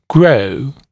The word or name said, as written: grow